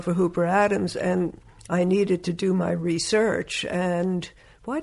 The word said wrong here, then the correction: research: You emphasized the wrong syllable. – I did indeed. Research.